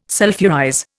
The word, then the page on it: sulphurize: Indian